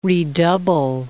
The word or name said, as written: redouble